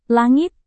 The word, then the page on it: langit: indonesian